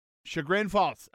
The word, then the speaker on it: Chagrin Falls: Ch- Chagrin? Chagrin? – Chagrin Falls. Okay, there you go.